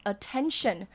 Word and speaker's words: attention: 再我们来看 attention 呢，指的就是注意力的意思。 – Attention 这个发音，第一个 A 就是发短音 e 的音，然后后面的 T E N 就像数字十一样，就是发 ten，然后后面的 T I O N 就是 tension，所以合起来就是念 attention。